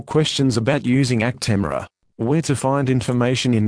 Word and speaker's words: Actemra: This is the consumer medicine information for Actemra and Actemra SC prefilled syringe, pronounced Actemra.